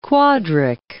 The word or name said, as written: quadric